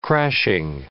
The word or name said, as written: crashing